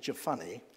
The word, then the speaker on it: Jephunneh: Now that's not how some of you might pronounce it. I pronounce it Jephunneh because when I was in Sunday school, I was taught that Joshua the son of Nun and Caleb the son of Jephunneh were the only two who ever got through to the land of milk and honey. So I pronounce Jephunneh because it rhymes with honey, okay?